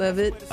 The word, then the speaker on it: Levitt: How do you pronounce his last name? – Levitt, is it? – Levitt. – Yeah, like that.